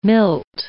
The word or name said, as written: milt